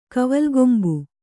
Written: ಕವಲ್ಗೊಂಬು

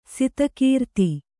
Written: ಸತ್ಕೀರ್ತಿ

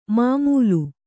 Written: ಮಮ್ಮಲ

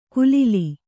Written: ಕುಲಿಲಿ

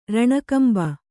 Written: ರಣಕಂಬ

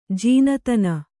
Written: ಜಾಣ್ತನ

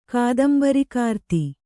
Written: ಕಾದಂಬರಿಕಾರ್ತಿ

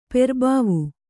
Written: ಪೆರ್ಬಾವು

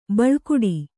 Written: ಬೆಲೆಗುಡು

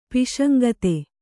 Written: ಪೈಶಂಗಿತ

